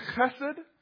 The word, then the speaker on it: chesed: It's that word that if you say it right, you spit on the front people sitting in front of you. Remember, it's the word chesed.